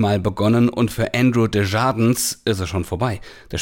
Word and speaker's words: Desjardins: Der Spieler, darf ich nochmal kurz, der heißt Desjardins, der wird so merkwürdig ausgesprochen. – Okay. Desjardins. – Andrew klar. Desjardins, ja.